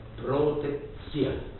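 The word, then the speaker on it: protekcja: Mi torna in mente che il termine locale, idice polacco, per indicare il privilegio era protexia, e si pronuncia protexia, ed è evidente l'origine italiana e latina.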